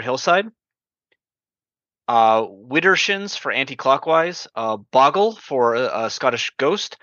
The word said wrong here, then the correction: Bogle: um, so, sorry, did I did I butcher that one? – No, no, Bogle. Oh, yeah, Bogle.